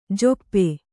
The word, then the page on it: ಝಾಪು: jhāpu